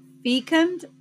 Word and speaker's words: fecund: It is spelled F-E-C-U-N-D, and it is pronounced either fecund or fecund.